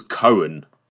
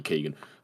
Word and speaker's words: Barry Keoghan: I never knew how to say it, I thought it was Cohen. I don't know why. And then I heard in an interview it was apparently Keoghan.